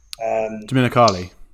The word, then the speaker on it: Domenicali: Domenicali.